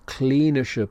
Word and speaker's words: klinisch: Ich studiere klinische Psychologie, clinical psychology. Klinisch, the I is long, klinisch.